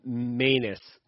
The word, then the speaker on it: Manus: And it's pronounced manus, as in anus.